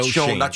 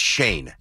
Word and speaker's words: Schoen: Is it Shane? – Not Sean. I thought it was Sean because it's spelled S-C-H-O-E-N. – I thought it was Sean as well. – Apparently, it's Shane. – It's Shane.